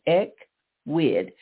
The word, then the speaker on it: Ecwid: Equid is spelled E-C-W-I-D, Equid, pronounced again, Equid.